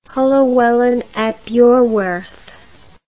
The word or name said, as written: Llywelyn ap Iorwerth